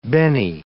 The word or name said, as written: bene